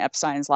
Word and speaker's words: Epstein: In September, we recorded a special, and then we did an addendum to that special about how to actually pronounce Brian Epstein's last name. And yes, it is Epstein.